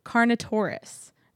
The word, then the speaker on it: Carnotaurus: But on your left, on the wall up high, there is actually a second B mode Carnotaurus.